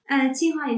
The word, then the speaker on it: Tsinghua: So I believe you first began studying in academia I'm going to try to pronounce this right, you might have to correct me, but it's Tsinghua University, did I get it right? – Tsinghua University in Beijing, in China, yes.